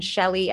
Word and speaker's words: Shelly: But people can honestly, you could just search in any search engine Shelly, S H E L L Y T Y G.